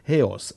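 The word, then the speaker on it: heos (ἕως): Allora, la parola in greco, dove dice finché, è la parola heos, H-E-O con l'accento S, heos. Cioè gli studiosi di greco abbiano pazienza, insomma, io lo leggo col dialetto, col mio accento, insomma.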